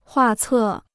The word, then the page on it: 画册: huà cè